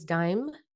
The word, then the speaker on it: Dime: My name is Dime, pronounced like the coin, but my full name is Diamond No.